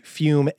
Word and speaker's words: Fum: We're talking about Fume, pronounced Fume, F-U-M-E, but spelled F-U-M.